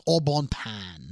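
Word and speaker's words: Au Bon Pain: By the way, Aubon Pain is pronounced Aubon Pan.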